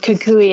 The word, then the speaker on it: Kukui: Was awarded the Kukui, did I say that right? – And Kukui, yes.